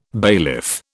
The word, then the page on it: bailiff: British English